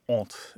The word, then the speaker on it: honte: And so, what's really interesting about that is the Cajun French kept the pronunciation of the H at the beginning of the word. It comes through a variety of dialect channels and stuff. Whereas mainstream French, like you would learn in school or that would be spoken in Paris, doesn't pronounce the H at the beginning of the word ont, as H-O-N-T-E.